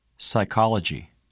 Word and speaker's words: psychology: The letter P is silent in the following words.Repeat them during the pauses. – Receipt. Psychology.